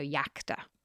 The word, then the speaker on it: iacta: And when it's a consonant, it's pronounced like a Y. So iacta, alia iacta est.